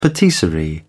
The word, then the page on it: patisserie: British English